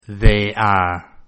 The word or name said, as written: they are